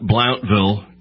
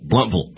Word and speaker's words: Blountville: And it's not Blountville, it's Blunt, and it's Vull. Bluntville. – Bluntville, yes. – Okay, there you go. B-L-O-U-N-T.